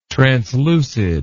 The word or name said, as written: translucid